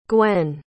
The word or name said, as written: gwen